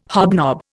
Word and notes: hobnob: Indian

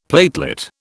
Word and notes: platelet: British English

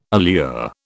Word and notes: allure: British English

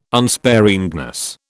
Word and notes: unsparingness: British English

